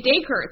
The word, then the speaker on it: Dacre: It's Acre with a D. Dacre.